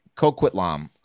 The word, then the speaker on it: Coquitlam: Coquitlam.